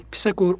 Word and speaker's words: Pisagor: Pitagorasçı okul, Pisagor olarak telaffuz edilebiliyor aynı zamanda. Daha çok Pitagorasçı okul deniyor.